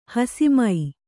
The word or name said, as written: ಹಸಿಮೈ